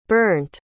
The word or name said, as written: bernd